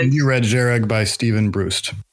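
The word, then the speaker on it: Jhereg: How do you pronounce the name of the book, Peter? – Zhereg. – Okay. Because a J next to an H does not make sense to me.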